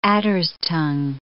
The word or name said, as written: adder's-tongue